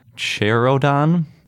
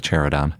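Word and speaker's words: Choyrodon: and this one's called Cherodon, is how I'm going to pronounce it. You're really supposed to roll the R and make it sound all Mongolian-like, which I cannot do. So I'm just going to stick with Cherodon.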